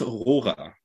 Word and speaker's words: Rohrer: Vincenz Rohrer is the pronunciation in German, but like honestly, Vincenz is easy for me. Rohrer is really tough to pronounce, especially in English.